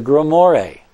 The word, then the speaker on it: grimoire: ask, what the heck is a grimoire? – Well, grimoire is a book of magic. – That's how you pronounce it? Grimoire? – Grimoire, yes. It's a book of magic.